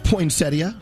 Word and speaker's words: poinsettia: Spelled poinsettia. Oh. The proper pronunciation, by the way. – P-O-I-N-S-E-T-T-I-A. Poinsettia.